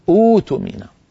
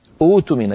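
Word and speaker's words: اؤتمن: فأؤتمن يبدأون بها أوتمن، أوتمن يبدلون الهمزة الساكنة الثانية حرف مد من جنس الأولى. ما حركة الهمزة الأولى؟ الضم. إذن يبدلون الهمزة الثانية واوا، فيصير النطق بها بدءا اختباريا لا يتعمد.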